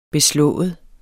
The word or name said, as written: beslået